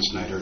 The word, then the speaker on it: Snyder: By the way, my name is Don Snyder. It's spelled S-N-Y-D-E-R.